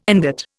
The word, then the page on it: ingot: Indian